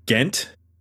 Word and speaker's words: Ghent: So you mentioned they are from, I'm probably not going to pronounce this correctly, but Ghent? – That's right, yeah.